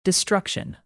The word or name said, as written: destruction